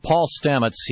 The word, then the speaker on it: Stamets: We're talking to Paul Stamets.